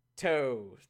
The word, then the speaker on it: Toews: Apparently, the letter did not sit well with their captain, Mr. Towns. Towns. What am I doing? What am I doing? – How do you say his name again? – Toes. Jonathan Towns.